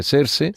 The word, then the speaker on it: Xerxes: Está ambientada, o Xerxes, ¿cómo se dice? Xerxes. – Sí.